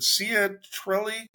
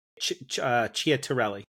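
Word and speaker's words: Ciattarelli: Is it Sia Trelli? – I think in Italian that C is a CH in his name. – Okay. – So it's Chiatarelli.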